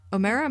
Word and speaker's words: Omera: But I saw a tweet that was talking about Omera. Omera, am I saying her name right? – Yeah. – Omera, okay.